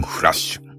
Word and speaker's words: F: えー、今回取り上げますのは、稲妻ンフラッシュ。えー、 F と書いてフラッシュと読みますね。